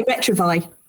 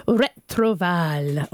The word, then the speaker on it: Retrouvaille: We're a community, and the correct, well, no, I'll give you my pronunciation with my cough, and also not with my best French accent, but it should be Retrouvaille. But we say Retrouvaille.